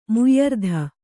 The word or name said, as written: ಮುಯ್ಯರ್ಧ